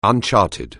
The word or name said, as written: uncharted